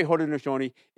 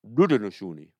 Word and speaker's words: Haudenosaunee: We say Haudenosaunee. In Mohawk, we actually say Rudenosaunee. We actually begin it with what you would say phonetically is an R instead of an H. But Haudenosaunee is what is commonly known.